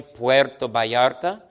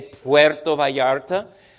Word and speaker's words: Puerto Vallarta: We should really pronounce that in a good Spanish way, Puerto Vallarta. Sounds a little bit different, doesn't it? And we always try to correct you all and have you say Puerto Vallarta.